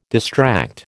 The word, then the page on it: distract: American English